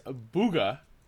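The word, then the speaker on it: Bugha: The 16-year-old known as Booga, I hope I'm saying that right, Kyle, yep.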